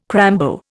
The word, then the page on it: crambo: Indian